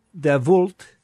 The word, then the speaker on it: Davoult: So I got a listener named Benjamin Davoult, D-A-V-O-U-L-T. – That's okay. Yeah, right? That's a fair pronunciation.